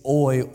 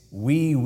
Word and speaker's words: oui: And of course, she said, I don't think it's oi, oi, oi. It's we, O-U-I, which is French for yes, which I pronounced as the well-read, well-spoken, respectable gentleman that I am, oi, oi, oi.